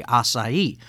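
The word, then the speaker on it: açaí: Uh, as I mentioned uh, in the opening, uh, today's podcast is being brought to you by the Blueberry Acai. I do want to stress uh, that acai is the correct way to say that, but uh, as I was sharing with someone earlier, the where I'm from, the the Clantonese, we would say blueberry acai.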